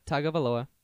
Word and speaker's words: Tagovailoa: A, Tua Tagovailoa.